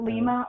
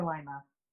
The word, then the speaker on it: Lima: Did I pronounce it Lima or Lima? – I pronounce it Lima. – I usually say Lima.